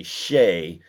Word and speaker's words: Shai: This podcast is known around some parts as the land of mispronounced names, and Shai Gilgeous-Alexander's name is actually Shay, but I'm not really one to talk, Brian, because I say people's names wrong all the time.